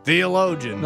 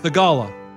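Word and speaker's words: Theegala: Dude, Thigala? – People get pissed. – Excuse me, I'm 100% sure you didn't pronounce that correctly. – Theologian. – No, Thigala. Thigala.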